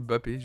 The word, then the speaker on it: Mbappé: D'ailleurs, je crois qu'on dit Mbappé. Je dis Mbappé à chaque fois, mais c'est Mbappé. Je crois que c'est Mbappé, juste. Le M ne se prononce pas.